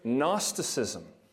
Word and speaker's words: Gnosticism: It's a big word, and it's such a big word that it starts with a silent G. You know you're in trouble if you're thinking about a word that starts with a silent G. Nothing good comes from that. It's the word Gnosticism.